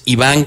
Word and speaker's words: Iván: Exactamente, Ángel, me puedes seguir por Facebook, Twitter, Insta, mi nombre es Iván